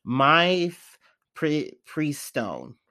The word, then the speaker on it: Mifepristone: What is it called? Mifistone? Jeez. I'm going to botch this pronunciation. Mifepristone. Mifepristone. No, it's definitely not Italian. I don't know. But Mifepristone.